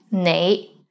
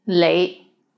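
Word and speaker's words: nei (你): The right way to pronounce it is nei, but in Hong Kong, many people pronounce it as lei, lei.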